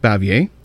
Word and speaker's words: Bavier: The film stars Michael Rennie, Patricia Neal, Hugh Marlowe, Sam Jaffe, Billy Gray, Francis, I believe it's pronounced Bavier, and Locke Martin.